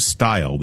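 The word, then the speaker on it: Steil: Stile, who's the chairman kind of leading the effort. – It's too bad that the congressman from Wisconsin pronounces his name Stile.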